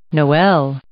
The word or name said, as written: noel